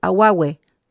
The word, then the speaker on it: awawe: Es como decir aguagüe, ¿sí? Sería, pero se escribe AWAWE. Pero se pronuncia, tal como digo, esta es la pronunciación que uno tendría en castellano, ¿cierto? Para que lo entienda bien.